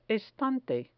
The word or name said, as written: estante